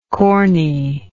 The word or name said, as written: corniness